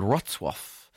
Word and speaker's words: Wrocław: It's a place called Wrocław, which is in Poland.